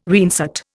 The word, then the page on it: reinsert: Indian